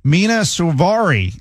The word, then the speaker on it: Mina Suvari: Mina Suvari. Is that how you pronounce her name? – Yes. Okay.